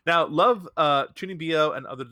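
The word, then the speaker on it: Chūnibyō: Chunbyo, and Other Delusions. – Isn't it Chunbyo? – Chunbyo, thank you.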